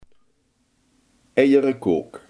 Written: omelet

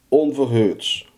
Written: onverhoeds

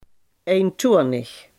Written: eentonig

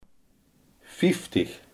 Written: vijftig

